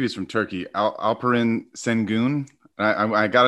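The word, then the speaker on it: Alperen Şengün: Alperen Sengun.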